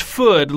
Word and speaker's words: Foodman: Pull the string to hear what entree Bunny is most thankful for, always along with a reminder that his last name is pronounced Food, like wood, and that he prefers Benny.